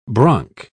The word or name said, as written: bronc